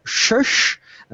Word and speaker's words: Shush: C'est Sush, pardon, je le prononce mal encore. Shush alors ça s'écrit S-H-U-S-H. Point d'exclamation.